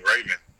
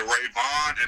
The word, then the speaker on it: Ravon: Man, it's kind of funny because, all right, first it was Ravon the Don Baxter, but nobody can ever pronounce my name right. They would call me Raven. And yeah. – The Ravon and the Don was supposed to rhyme, so I was like, man, I gotta change it.